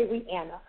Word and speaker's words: Rihanna: This one is from Rihanna, and she says it's pronounced Anna in the middle. Okay, Rihanna.